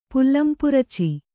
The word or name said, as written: ಪುಲ್ಲಂಪುರುಚಿ